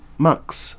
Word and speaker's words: MX: How do you pronounce the title? Mux. So it's MX.